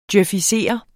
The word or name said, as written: djøfisere